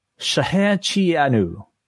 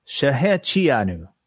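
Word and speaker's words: Shahechianu (שהחיינו): you might have to help me out here, John, because John Pine, or Rebar Mitzvah Revere himself, has a proper pronunciation. Thank you so much, John, for the super chat. He says, Shahechianu. – Nailed. – Shahechianu.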